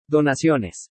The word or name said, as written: DONACIONES